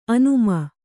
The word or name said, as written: ಆನೆಮೀನು